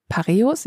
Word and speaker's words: Pareos: Pareos, ja, ist auch richtig ausgesprochen.